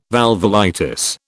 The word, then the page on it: valvulitis: British English